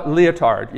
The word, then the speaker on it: Lyotard: He's talking about a guy, Jean-Francois Lyotard. Yeah, just like the thing that you might wear if you're a dancer. That's how you say it.